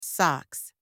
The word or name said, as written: socks